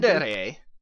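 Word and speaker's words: tsundere: Sunder? This is like an anime term that she tried to explain. – Sundere, I think is how it's pronounced.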